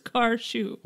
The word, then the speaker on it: Car Shoe: Sounds like you're saying cashew weird. Car Shoe. – No, it's two words. Car Shoe.